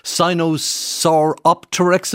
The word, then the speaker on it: Sinosauropteryx: It was Sinosauropteryx, if I'm not mistaken. Yeah, I'm sure I'm... – That's it. You've got your pronunciation correct.